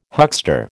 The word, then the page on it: huckster: American English